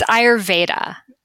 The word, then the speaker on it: Ayurveda: So Ayurveda. Like Ayur, and it means the knowledge of life. So Ayur, life, Veda, knowledge. So Ayurveda, in Hindi, it's Ayurveda because you pronounce V's like W's.